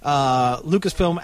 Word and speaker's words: Lucasfilm: We are talking, of course, about the Lucasfilm acquisition by Disney.